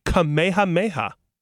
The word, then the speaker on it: Kamehameha: All right, wait, before I even try, do you know how to pronounce this? – Actually, I do. Don't ask me why. His name is King Kamehameha the Great.